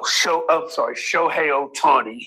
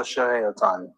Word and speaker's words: Shohei Ohtani: Oh, Shohei Ohtani. – Oh, Shohei Ohtani.